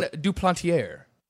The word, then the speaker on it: Duplantier: You use the R. You throw the R in there in the end. – John Duplantier.